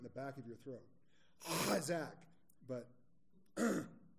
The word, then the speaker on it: chazaq: And this word in Hebrew, forgive my Hebrew pronunciation because it's not very good, is C-H-A-Z-A-Q, Kazak.